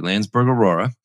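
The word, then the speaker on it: Landsberg Orora: Well, first of all, I want to say that you said it correctly, Landsberg Aurora.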